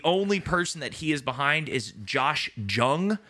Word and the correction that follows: Jung: Young. – Oh, is that how it's pronounced? – You should know that.